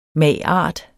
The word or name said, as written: mag.art.